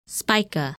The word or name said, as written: Spica